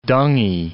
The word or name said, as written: dungy